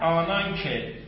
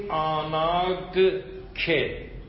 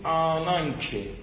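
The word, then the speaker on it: آنان که (ἀνάγκη): اما گاما اگر بخوره به کاپا، یعنی قبل از کاپا قرار بگیره، نو تلفظ میشه، یعنی نو، نون، نو. متوجهی؟ ما باید بگیم آناگ که، اما نمیگیم، میگیم آنان که.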